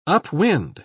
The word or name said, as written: upwind